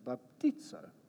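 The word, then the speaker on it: baptizo (βαπτίζω): Maybe you just say to the person next to you, baptizo. Emphasis on the right, sound like a Greek person.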